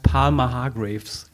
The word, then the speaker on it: Palmer Hargreaves: Äh, Executive Creative Director bei Palmer Hargreaves, ist das richtig ausgesprochen? – Ja, fast. – Ja. Ähm, nah genug dran. Ich glaube, es heißt Palmer Hargraves.